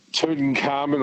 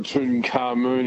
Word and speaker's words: Tutankhamun: Tutankhamun or Tutankhamun, you can pronounce it either way.